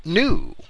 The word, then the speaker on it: gnu: And from there, in certain European languages, the G was eventually dropped from the pronunciation. And there are clear examples of that in the English words gnome, G-N-O-M-E, and gnu, which is G-N-U, referring to the animal.